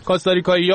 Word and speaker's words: کاستاریکا: البته خود کاستاریکا کلا کاستاریکایی ها و این اهالی خود خارجی و اینا که انگلیسی ها خیلی بد تلفظ میکنن این کاستاریکا رو، اسمش کاستاریکاست دیگه، من نمیدونم چرا انقدر بد تلفظ میکنه.